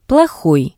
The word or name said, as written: plohoi